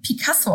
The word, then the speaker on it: Pacaso: Pacaso, ne? – Genau, ich glaube, die werden ähm, Picasso ausgesprochen, werden ein bisschen anders Ach. geschrieben.